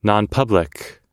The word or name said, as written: nonpublic